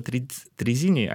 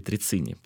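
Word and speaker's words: Trezzini: Мы называем его трезине, а его фамилия на самом деле Трицини, потому что 2Z читается как С, пицца.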